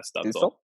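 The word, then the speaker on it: Stützle: Yeah, Stutzel.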